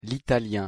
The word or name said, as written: l'italien